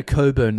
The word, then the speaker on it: Cockburn: But, you know, those people with those names, you know, Coburn that's spelled Cockburn.